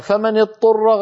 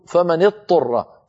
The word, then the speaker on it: اضطر: يقول فمن اضطر، فينطق طاء مشددة، والعرب لا تفعل هذا، يعني يدغم الضاد في الطاء، والضاد لا تدغم في الطاء، لابد من بيان الضاد وبيان الطاء.